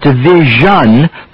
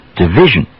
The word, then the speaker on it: division: Now try the sound in these S-I-O-N endings. Division. Again, most Americans don't pronounce the vowel fully in the zhun syllable. Not division, but division. Explosion. Vision. Confusion. Decision. Of course, words ending in S-I-O-N are not the only ones that contain the sound zh.